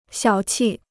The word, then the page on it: 小气: xiǎo qì